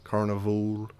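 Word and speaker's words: Karnivool: This band is called Carnival. That's with a K. Well, I can spell it. It's spelled weird. It's K-A-R... N-I-V-O-O-L, Carnival.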